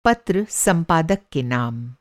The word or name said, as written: पत्र-संपादक